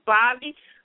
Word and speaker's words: Bobbie: My YouTube channel is Bobbie Clark Alexander. That's B-O-B-B-I-E.